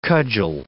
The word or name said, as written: cudgel